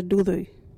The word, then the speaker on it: duthu: What is a dudhu? Dudhu is a dog. You can use dudhu in this sentence. Look out, dudhu coming. Or, that dudhu is following us. Dudhu is spelt D-U-T-H-U. Can you say dudhu?